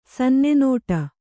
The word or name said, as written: ಸನ್ನೆನೋಟ